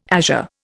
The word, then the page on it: azure: Indian